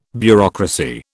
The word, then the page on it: bureaucracy: British English